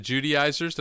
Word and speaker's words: Judaizers: Paul wrote Galatians to combat the Judaizers. Did I say that right? – Yes.